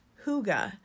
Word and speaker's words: hygge: It's spelled H-Y-G-E-E, but is pronounced hygge because it comes from the Danish.